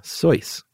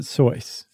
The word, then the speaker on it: Seuss: Soyce. – Right. S-E-U-S-S again. But he pronounced it Soyce.